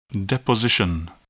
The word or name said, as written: deposition